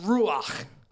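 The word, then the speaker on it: ruach (רוּחַ): The Old Testament word, it's a Hebrew word for spirit, and it's pronounced ruach. Okay, kind of like you have popcorn stuck in the back of your throat.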